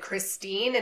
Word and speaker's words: Christine: He should have been able to see that my name is Christine and pronounce it correctly.